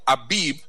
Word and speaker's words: Abib: And we had the incident of Abib, which is spelled A-B-I-B, a company, a virtual mobile operator, and 31,000 data records were exfiltrated.